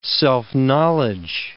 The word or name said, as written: self-knowledge